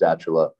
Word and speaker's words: Stachela: That was the case with Johnny Stachella, and it is pronounced Stachella. And don't worry, everyone messes that name up.